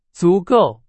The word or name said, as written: 足够